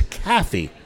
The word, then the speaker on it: Kafie: But it's Kathy. That's K- correct. It's spelled K A F I E, and I it just looks like it would be coffee to me, like not like coffee, like coffee talk.